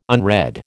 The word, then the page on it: unread: American English